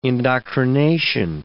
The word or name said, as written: indoctrination